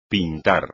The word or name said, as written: pintar